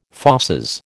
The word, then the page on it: fauces: American English